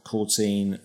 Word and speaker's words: Courteen: So I'm at Dave Courteen. Mm-hmm. My surname is spelt Courteen as though it was 14, but with a C instead of the F. So that's the I'm at Dave Courteen.